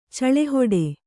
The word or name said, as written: ಚಳೆಹೊಡೆ